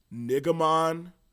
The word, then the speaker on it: Negamon: And the Digimon's name is spelled N-E-G-A-M-O-N and pronounced Negamon.